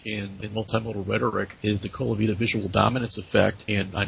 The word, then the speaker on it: Colavita: Colavita, C-O-L-A-V as in Victor, mm-hmm. I-T-A, mm-hmm.